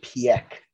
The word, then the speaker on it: Piëch: Yeah, it's usually pronounced Piëch, is from what I've seen.